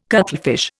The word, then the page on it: cuttlefish: Indian